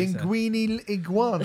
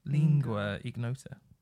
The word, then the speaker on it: Lingua Ignota: Linguini Iguana. – Linguini Iguana. – I've never... What? – Linguita Ignota. – It's not Linguita, because it's spelled L-I-N-G-U-A. So it's Lingua. Lingua Ignota, I think.